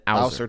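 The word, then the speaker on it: Ausar: See, I Googled how to pronounce his name, and it said Asar Thompson. Yeah.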